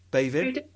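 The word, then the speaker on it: Béibhinn: Bavin Parsons. Yeah. Guess who I asked for the pronunciation? Lindsay Pete. She gave me the pronunciation. It means white lady, Bavin.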